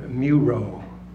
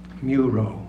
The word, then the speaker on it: Muro: I wrote a text which is in my book M, called Muro. That is the first syllable of music, followed by the second syllable of Thoreau, Muro.